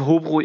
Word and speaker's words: Hobro: Ähm, der bekannteste Spieler, vielleicht äh, zumindest in Dänemark, von Höbro, habe ich das jetzt richtig ausgesprochen? – Äh, Hobro Ikor, ist, ja.